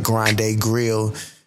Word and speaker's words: Grande Grille: And far as meal prep goes, you can also find me on IG at Grande Grille, and spell that is G R A N D E G R I L L E. That's Grande Grille, pronounced Grande Grill.